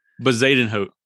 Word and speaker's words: Bezuidenhout: But next up, I've got, don't even know how to say his last name, but Christian Bezuidenhout. – Bezuidenhout. – Bezuidenhout.